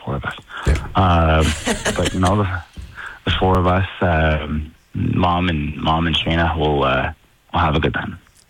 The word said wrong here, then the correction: Shanae: I think you mispronounced her name. It's Shanae.